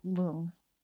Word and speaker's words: rlung: So lung in a Tibetan transliteration is spelled R-L-U-N-G, but the R is silent, so we'll call that lung.